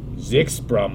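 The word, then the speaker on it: Kshipra: They have Kispra Marma, pronounced as Zixpra Marma.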